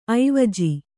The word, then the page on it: ಐವಜು: aivaju